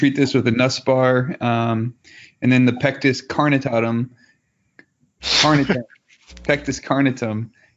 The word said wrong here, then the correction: carinatum: How do you say it? – Carinatum.